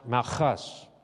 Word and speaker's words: Malchus: It's actually pronounced Malchus, but I'm... what's that word? I'm hooked on phonics. So I'm going to say it the way I understand it, Malchus.